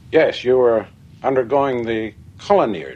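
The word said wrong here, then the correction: Kolinahr: discipline. – Sit down. – If you are referring to the Colinar, Doctor, you are correct.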